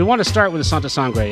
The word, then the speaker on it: Santa Sangre: And also, we're talking about Santa Sangre.